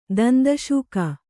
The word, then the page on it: ದಂದಶೂಕ: danda śuka